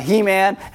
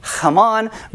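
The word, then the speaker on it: Heman: And this psalm is by He-Man and the Power of Grayskull. No, technically you'd probably say his name Haman,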